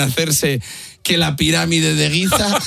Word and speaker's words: Giza: Giza.